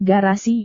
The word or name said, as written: garasi